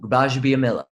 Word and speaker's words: Gbajabiamila: How do you pronounce Akbar's last name? – Ooh, Gbajabiamila. – Oh, can you spell it? – No. That's with G though.